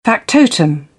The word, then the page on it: factotum: British English; əʊ